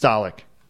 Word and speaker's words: Dalek: It says D-A-A-L-U-H-K. I would assume that means da. Dalek. – Dalek. – Well, let's see. I think I can make it say it. Yep, it's Dalek.